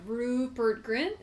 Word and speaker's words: Rupert Grint: I just, he exudes the same energy as Rupert Grint. Is that how you say his name? – Uh-huh.